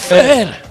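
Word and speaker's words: Fher: Fer. Así se pronuncia, Fer.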